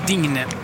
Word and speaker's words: Digne: Lucas Digne slutar också på E men uttalas inte så där som Mbappé E.